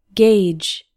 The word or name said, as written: gauge